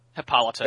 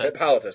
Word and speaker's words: Hippolyta: Hippolyta? – Hippolyta. – Hippolyta, sorry.